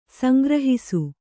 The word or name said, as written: ಸಂಗ್ರಹಿಸು